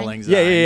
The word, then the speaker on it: Auld Lang Syne: How's it pronounced? – Auld Lang Syne. Auld Lang Syne. – Yeah. I always fuck that up.